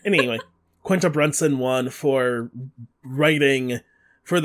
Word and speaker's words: Quinta: Quinta. Quinta Brunson. – Quinta Brunson. I always say Quinita, and I don't. I like I switch the I and the N.